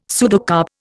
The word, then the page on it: pseudocarp: Indian